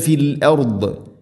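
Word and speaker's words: ഫിൽ അർദ് (فِي الْأَرْضِ): വയുഫ്സിദൂന ഫിൽ അർദ്, ഫിൽ അർദല്ല ഫിൽ അർദ്, ഫിൽ അർദ് ഫിൽ അർദ്, വഖഫ് ചെയ്യുമ്പോൾ അർദ്, ഇങ്ങനെ ദാദിനെ പ്രത്യേകം ശ്രദ്ധിച്ച് ഉച്ചരിക്കണം.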